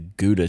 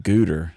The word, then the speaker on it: Gouda: But Justin wanted some of the Gouda cheese, but he pronounced it Gouder. So I guess at some point we missed the memo that they upgraded Gouda to Gouder.